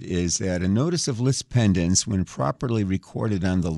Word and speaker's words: lis pendens: This is pronounced lis pendens, same thing. It's spelled L-I-S. The last word is P-E-N-D-E-N-S.